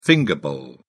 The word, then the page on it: finger bowl: British English